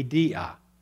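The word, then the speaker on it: dia (διά): In fact, when it says one God and Father of all, who is through all, the word through there is a word that you would pronounce in the original language, the Greek language, calls different ones would pronounce a different way. In my training, it would be dia.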